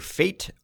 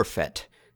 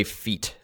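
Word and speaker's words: Fett: By the way, it's pronounced either fate or fet. Evidently, both are acceptable. I'm going to go with fet, because fate is a word and fet is just a bounty hunter. So I'm going with fet. You can't say feet. I guess feet is probably not permissible. That is already a different word.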